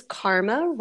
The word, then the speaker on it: Karma: Well, my name is Karma Rains. Yes, that is my real name. K-A-R-M-A.